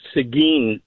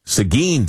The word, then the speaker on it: Seguin: The little town is actually pronounced Seguin, Texas. – Seguin, Texas. And where is that? – That's 40 miles east of San Antonio, off of the Interstate 10. – And that is the pecan capital of the world. – Yeah, he was saying Seguin, and the only reason I called, because he did mention, I don't know if I'm pronouncing it right, so I just wanted to call it. It's actually called Seguin, Texas.